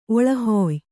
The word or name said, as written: ಒಳಹುಯ್